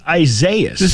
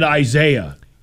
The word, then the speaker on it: Isaias: And I'm like, how do you not know that that's just pronounced Isaias? Why can't you just say that word? – Because he's never actually seen it written down before. – Okay, but somebody tells you it's pronounced Isaias. Okay, fine. I'm pronouncing it Isaiah.